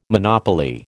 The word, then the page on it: monopoly: American English